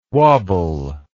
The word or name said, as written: wabble